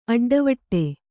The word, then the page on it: ಅಡ್ಡವಟ್ಟೆ: aḍḍavaṭṭe